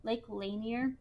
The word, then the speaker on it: Lanier: was left in a stream bed next to Lake Lanier. I'm pretty sure I'm pronouncing it right. Lake Lanier. – Yeah.